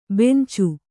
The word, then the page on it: ಬೆಂಚೆ: bence